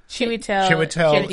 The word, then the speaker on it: Chiwetel: Chiwetel? – Chiwetel Ejiofor.